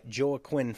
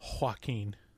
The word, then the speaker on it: Joaquin: And so Ivy Walker and Lucius Hunt, played by Joaquin Phoenix, fall in love and are set to be married when a violence occurs, leaving Lucius... – David, I believe it's pronounced Joaquin. Joaquin Phoenix.